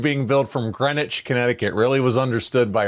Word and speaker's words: Greenwich: Yeah, well, it's spelled Greenwich. It's Greenwich.